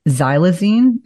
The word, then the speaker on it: xylazine: And I just, I think I'm on a fentanyl curiosity focus at this point, but xylazine, am I pronouncing that properly? – Oh, yeah, yeah, absolutely.